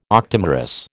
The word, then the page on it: octamerous: American English